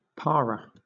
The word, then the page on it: para: British English